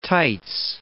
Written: tights

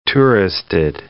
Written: touristed

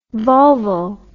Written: volvo

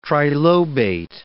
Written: trilobate

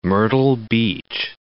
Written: Myrtle Beach

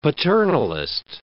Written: paternalist

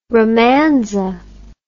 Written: romanza

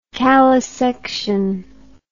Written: callisection

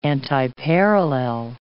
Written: antiparallel

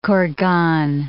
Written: kurgan

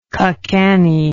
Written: cacanny

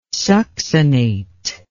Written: succinate

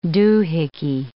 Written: doohickey

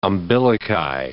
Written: umbilici

